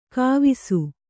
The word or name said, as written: ಗವಿಸು